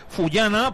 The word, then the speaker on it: Fullana: pero que desde ahí Fullana podría ponerla. Lo he dicho bien ahora, ¿eh? – Ahora sí.